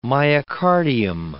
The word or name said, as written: myocardium